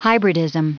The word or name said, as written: hybridism